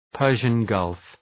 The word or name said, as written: Persian Gulf